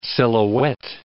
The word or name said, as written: silhouette